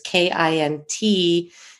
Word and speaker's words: kintsugi: So there's two different ways. There's the longer version, which is scarier, which is kintsukuroi. And then there's the two word version, which I'm pronouncing, which is kintsuegi.